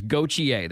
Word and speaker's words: Gauthier: I know the pronunciation of the name does not sound like it. It looks. I had to call Nick the second that trade went down and say, all right, how in the world do I pronounce this one? Glad I got it right, so say it with me, Hawks fans. Gauthier, that's how you say it.